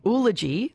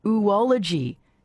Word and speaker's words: oology: Jessica in Druin says, I've been saying oology, the study of eggs. Jessica says oology is actually pronounced oology.